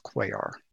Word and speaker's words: Cuellar: Do you think that's Sueller? – It would be pronounced Cuellar. – Cuellar? – Yes. – Okay. That's C-U-E-L-L-A-R.